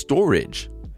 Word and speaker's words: Storj: It's Storj. That's how it's pronounced, it's, but it's spelled S T O R J.